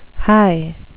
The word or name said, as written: hie